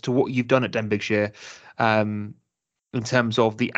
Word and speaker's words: Denbighshire: And today, I'm absolutely delighted to have Jamie Groves, the managing director of, and I'm going to pronounce this wrong, Denbighshire Leisure Limited. Um, Jamie, thank you for coming on. How are you? Yeah, good, good. Thanks for having me. Did I pronounce that correctly? – You did, spot on.